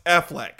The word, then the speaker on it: Affleck: Oh, Jordan, you've probably only read that before and never said it out loud. – What? – But it's pronounced Affleck.